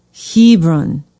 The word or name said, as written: hebron